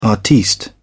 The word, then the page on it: artiste: British English